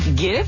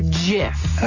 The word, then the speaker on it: GIF: Wait, GIF or JIF? Uh, hard G.